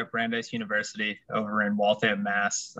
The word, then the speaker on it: Brandeis: Yep, Brandeis University. Brandeis, got you.